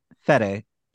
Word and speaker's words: Fede: Freddy Alvarez, there we go. – Say his name again, the first name. – What, Freddy? – It's Fede. F-E-D-E.